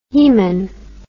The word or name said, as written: haemon